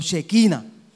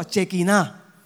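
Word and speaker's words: Shejiná: Pero en, el español es chequiná. Ahora, ahora, ahora me dicen. O chequina. Pero en el hebreo se pronuncia chequiná.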